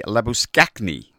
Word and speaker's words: Labuschagne: Well, as they say, Labuschagne. Is how you pronounce it.